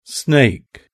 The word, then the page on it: snake: British English